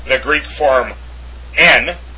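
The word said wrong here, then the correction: hen (ἕν): or hen actually, I'm sorry, meaning one, it's pronounced hen, because over the epsilon, there's a rough breathing mark. So, epsilon nu with a comma that is rough is pronounced hen.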